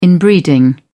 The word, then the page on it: inbreeding: British English